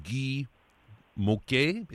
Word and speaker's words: Guy Môquet: And the young man that you highlight in the first chapter of the book is one Guy Môquet. Is that how we pronounce his name? – Correct.